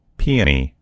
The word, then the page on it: peony: American English